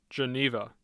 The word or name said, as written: Geneva